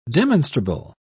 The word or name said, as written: demonstrable